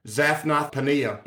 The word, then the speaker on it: Zaphnath-Paaneah: And Pharaoh called Joseph's name Zaphnath-Paaneah. That's how I pronounce it. And I have actually a program that tells me that's how it's pronounced. Zaphnath-Paaneah.